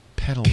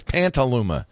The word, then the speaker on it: Petaluma: Petaluma. P-E-T-A-L-U-M-A. – Hey, he did it right. No N. Usually he adds Pantaluma.